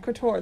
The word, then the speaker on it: couture: She was dressed head-to-toe in 2008 Hot Topic couture? I don't know how to pronounce it. – Couture, that's correct.